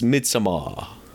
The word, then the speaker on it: Midsommar: That is how it's pronounced. – But it's Midsommar.